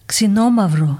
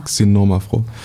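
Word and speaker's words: Xinomavro: Xenom Xenomavro. – Xenomavro.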